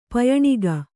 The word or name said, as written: ಪಯಣಗೈ